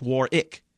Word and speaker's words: Warwick: His middle name is spelled with a W, two W's, Warwick Montgomery, but it's pronounced Warick.